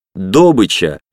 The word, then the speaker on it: добыча: Они всегда говорят «добыча» с ударением на первый слог.